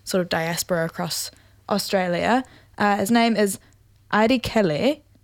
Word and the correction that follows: Airi Leke: Oh, no, sorry. I butchered that. Aidi Leke, I think is how you say that. A-I-R-I-L-E-K-E is how you spell it.